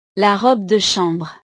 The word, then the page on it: la robe de chambre: frances